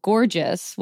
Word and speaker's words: Gorjs: And more recently, as I mentioned in the intro, L'Oreal launched NYX's Beauty DAO Gorgeous, which sounds like the word gorgeous, but it's spelled G-O-R-J-S.